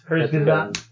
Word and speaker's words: Kurzgesagt: Kurzgesagt. Kurzgesagt. – Kurzgesagt.